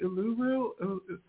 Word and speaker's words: Uluru: And you went to, was it Uluru? I never know how to pronounce it in Australia. – That's right.